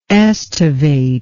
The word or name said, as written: estivator